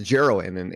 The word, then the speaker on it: Jeroen: The name, you know, because Jeroen's name is spelled J-E-R-O-E-N. So me is the typical American. Is it pronounced Jeroen? And obviously, yeah, it's Jeroen. I get all kinds of pronunciations like Jeroen, Jeroen, whatever, everything.